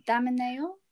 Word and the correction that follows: Damiano: No. – No, it's wrong. Damiano. See. – Yeah. – Because I think it's close. It's okay. – Yeah. Damiano.